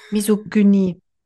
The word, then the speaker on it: Misogynie: Miso, Miso wie Misosuppe. – Misogynie.